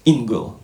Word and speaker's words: Engle: ten autor tej książki, ja nawet nie wiem, jak jego nazwisko przeczytać. Lou Engel, Engel, ktoś wie, jak to się czyta? Nazwisko? Pisze się Engle. – Ingle. – Lou Ingle. Dzięki.